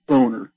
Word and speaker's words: Boehner: Him, yeah, friggin' weird-ass John Boehner, or Boehner, his real name's Boehner. – His real name, yeah, right. No, that's how he pronounces it.